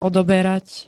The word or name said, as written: odoberať